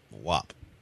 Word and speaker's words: WAP: W-A-P. W-A-P. – Oh, WAP. – Hold on. – WAP. – That's WAP? – It's pronounced WAP.